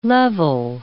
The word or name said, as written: level